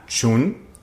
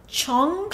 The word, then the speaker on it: Chun: Entonces, cuando se lleva todo el equipo de Camp, el CEO de Star Crew Entertainment, que se llama Chun. – Sí, yo lo escuché pronunciar como Chong.